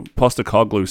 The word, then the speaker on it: Postecoglou: So Postecoglou